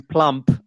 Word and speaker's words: plump: should have been plump. I've just hmm. realized mm. that plum is actually a, a, a, a fruit. fruit. Hmm. It's yes. fruit. So plump with p, p sound at the end just a little correction.